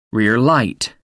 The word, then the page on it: rear light: American English